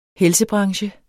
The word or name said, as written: helsebranche